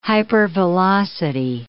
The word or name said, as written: hypervelocity